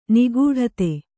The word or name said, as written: ನಿಗಡಿತ